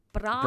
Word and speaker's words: Brahe: Scandinavian, and it's spelt B-R-A-H-E. Brahe? – Brahe. – Okay. Ebba Brahe.